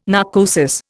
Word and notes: narcosis: Indian

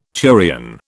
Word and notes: turion: British English

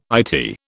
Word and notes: Eyetie: British English

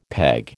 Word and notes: peg: American English

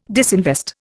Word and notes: disinvest: Indian

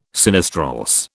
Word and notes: sinistrorse: British English

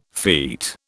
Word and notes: feet: British English

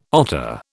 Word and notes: otter: British English

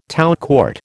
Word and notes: tout court: American English

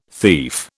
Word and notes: thief: British English

